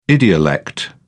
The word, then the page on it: idiolect: British English